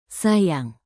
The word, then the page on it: sayang: indonesian